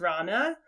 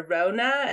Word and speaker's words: Rana: Like, my mom's name is Rana. So a lot of people call her Rona, and that's not great.